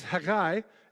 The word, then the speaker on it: Haggai: Now, this is Haggai, is how you say this. It's not Haggai, it's Haggai. And actually, it's that H is the actual CH in Hebrew.